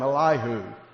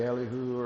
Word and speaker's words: Elihu: Then was kindled the wrath of, and I'm going to call him Elihu. That's how I grew up pronouncing it. Some say Elihu, or I'll say Elihu, the son of Barachah, the Buzite of the kindred of Ram.